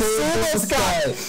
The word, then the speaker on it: Sabulsky: She's, did I say that right? – Sabulsky. – Sabulsky. Nadine Sabulsky. This is why it's hard to do these things live.